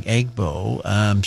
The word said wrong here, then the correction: Igbo: She's actually done some research. Thank you for Shirley for doing that. And she thinks the actual pronunciation is Igbo.